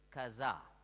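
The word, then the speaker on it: chazah: Listen, that word behold is the Hebrew word kazah. C-H-A-Z-A-H. C-H-A-Z-A-H. Kazah. C-H in Hebrew is pronounced K. Kazah.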